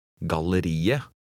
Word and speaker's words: galleriet: In number 7, remember that the final T is not pronounced. – Les nummer 7. – Galleriet.